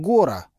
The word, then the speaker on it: Гора: Вечером встречался с Павлом Гора, ударение на О».